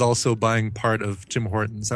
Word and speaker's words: Hortons: Hortons!